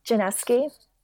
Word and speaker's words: Janiszewski: Did I get your name right, Sarah? – Close. Januszewski. – Januszewski. – Yes, close. I know, lots of extra letters.